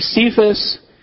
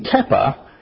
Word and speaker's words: Kepha: In Galatians 2, verse 9, Paul writes, "And when James, Cephas, and John," okay, Cephas is a mispronunciation of his Hebrew name, Kepha, commonly called Peter.